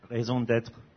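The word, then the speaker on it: raison d'être: Raison d'etre.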